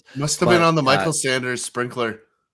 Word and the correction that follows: Saunders: Saunders, say his name right. Saunders, Michael Saunders.